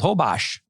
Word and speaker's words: Holbox: We drove about 3 hours through the Yucatan to a little island off the north coast called Hobosh. – Not Holbox. Which is how it's spelled. So when you right. – see it, people, it's Hobosh.